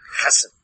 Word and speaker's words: chesed: Yeah, you gotta pronounce it deep down. Chesed. Yeah, I wanna see a little spit coming out of your mouth.